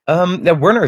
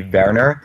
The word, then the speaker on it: Werner: Werner is totally correct. Okay. So if you would pronounce this in Dutch or German, like Dutch would be Werner, and if you would go German, it's like Werner.